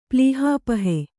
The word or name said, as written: ಪ್ಲೀಹಾಪಹೆ